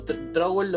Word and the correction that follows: Strogel: lo, pronuncié yo mal, Strogel Online de Nicolás Meneses editado por MC.